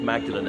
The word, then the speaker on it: Magdalene: I know in Oxford there's Magdalen College, spelt M-A-G-D-A-L-E-N, but the church, methinks, is still pronounced Magdalene as St. Mary Magdalene, the woman who's mentioned on the Bible on Via Dolorosa.